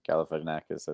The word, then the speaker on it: Galifianakis: Yeah, Galifianakis, I think.